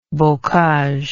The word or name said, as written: bocage